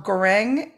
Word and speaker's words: goreng: And I don't know if I'm pronouncing it right, but NASI, Nasi, Goreng, or Goreng, GORENG. – You are saying it correctly.